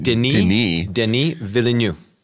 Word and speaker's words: Denis Villeneuve: Denis is for, you pronounce it Denis Villeneuve.